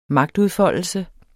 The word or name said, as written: magtudfoldelse